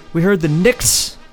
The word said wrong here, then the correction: Nichts: I thought that was nichts.